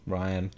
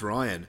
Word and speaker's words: Rian: Ryan. – Who I'm guessing... Oh, is it Ryan? Oh, is it pronounced Ryan? Yeah. That's a silly way of saying Ryan.